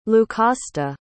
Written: lucasta

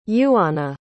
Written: iuana

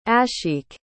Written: ashiq

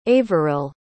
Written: averil

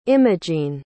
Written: imogene

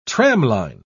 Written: tramline

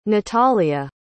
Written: natalya

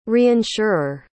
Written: reinsurer

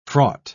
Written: fraught

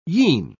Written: yean

